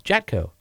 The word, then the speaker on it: Jatco: Jatco. – Spell that. – J-A-T-C-O.